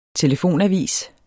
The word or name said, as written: telefonavis